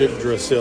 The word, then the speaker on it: Yggdrasil: But it has all about the, I can never pronounce it, Yggdrasil? You know, that tree of life, whatever the kids call it. – Yggdrasil.